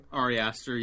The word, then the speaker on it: Ari Aster: Ari Aster, yeah.